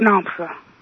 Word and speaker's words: Nomsa: My name is Nomsa. – Nomsa? – Uh-huh. – Spell it for me. – N-O-M-S-A.